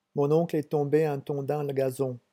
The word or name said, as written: mon oncle